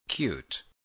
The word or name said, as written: cute